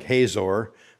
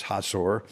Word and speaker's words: Hazor: Now that word you might say looks like Hazor, but it's actually pronounced Hazor.